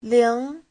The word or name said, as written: ling2